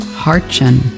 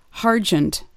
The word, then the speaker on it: Hartchen: For a long time, I probably, like many others, wanted to pronounce yours and Brian's last name as Hargent. I don't know why. And what lots of people pronounce it either Hartchen or Hargent, or it's really rare to hear it pronounced properly, but it's Hartchen.